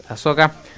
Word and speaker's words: Ahsoka: Por ejemplo, la serie de Ashoka, eh, va, Ahsoka, digo porque lo pronuncio yo así, Ashoka, pero es Ahsoka.